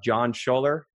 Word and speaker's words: Jon Schoeller: I have John Schoeller.